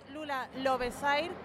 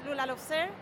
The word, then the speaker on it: Lula Lovesair: Sí, a ver, mi nombre es Lula Lovesair, dicho mal dicho, en verdad es Lula Lovesair, la pronunciación, y me pueden buscar con ese nombre, tanto en Facebook como en Instagram, Twitter.